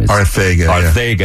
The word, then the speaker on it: Arcega: Arcega. That's it in Spain, yeah. – So he goes, J.J. Arcega is from Spain, speaks three languages, and wants us to pronounce his name like Mike Tyson.